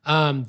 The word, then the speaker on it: Sewell: Sewell.